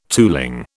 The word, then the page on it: tooling: British English